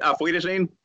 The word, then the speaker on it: Auf Wiedersehen: Auf Wiedersehen. Auf Wiedersehen. Yeah. yeah Perfect. yeah, I, I remember what everybody was telling me when I was over in Munich. Auf Wiedersehen.